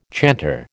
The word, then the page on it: chanter: American English